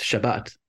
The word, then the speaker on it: Shabbat: If we want the right pronunciation, it's Shabbat.